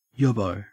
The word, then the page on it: yobbo: Australian English